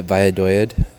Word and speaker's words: Valladolid: That's how you say it, by the way, Valladolid.